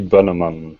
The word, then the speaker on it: Bönnemann: And did I say your last name right? – That would be Bönnemann.